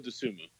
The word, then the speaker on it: Dosunmu: How do you say it? – Ayo Dosunmu.